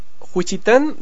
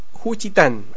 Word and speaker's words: Juchitán: Mariana Vicenta Gil, de, aquí tengo que tener cuidado con la pronunciación, dice Juchitán de Zaragoza, Oaxaca, México. Es Juchitán, eso es, de Zaragoza, Oaxaca, México.